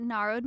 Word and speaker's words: Narod: You can find me at Narod Michelle, N-A-R-O-D Michelle. – She's the cutest. And don't ever call her, don't ever mispronounce her name. It's Narod. Like, nah, take the road.